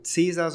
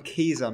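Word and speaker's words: Caesar: wird, aber eigentlich wird das C wie ein K ausgesprochen. – Genau. Deswegen heißt es auch nicht äh, Cäsar, sondern Käser müsste es eigentlich heißen.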